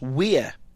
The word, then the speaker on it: Wear: You see, in America, you look at W-E-A-R, and it's where. But it's in the pronunciation of the proper river's name, it's where.